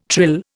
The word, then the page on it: trill: Indian